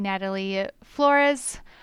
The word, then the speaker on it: Natalie Flores: Today, we are joined by Natalie Flores.